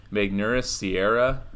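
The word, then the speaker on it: Magneuris Sierra: Magnus Sierra, I think is how you say it.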